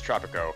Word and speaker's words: Tropico: this game, Tropico, if I'm even saying that right, Tropico? Yeah, it's Tropico.